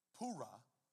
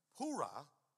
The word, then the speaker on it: Purah: But if thou fear to go down, go thou with Purah, is how you say that name. I looked it up today. Purah, thy servant, down to the host.